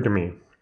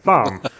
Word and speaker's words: Thom: And it never occurred to me that Tom's name is pronounced Tom. – Oh no. – Because there's an H in there. And I was like, Thom. His name is Thom.